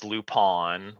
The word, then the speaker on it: Lupin: So the Netflix series is Lupin, which...